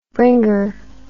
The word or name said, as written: bringer